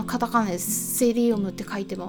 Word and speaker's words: psyllium: みっていうとちょっと分かりづらくなってしまうかもしれないんですけれども、ま本格的に英語で言うとあの実はねサイリウムではなくてねセリウムっていうんですね A 文字の PSYLLIUM と書いてセリウムって読むんですけれどもこれなんか日本語だとサイリウムって読むみたいなんですね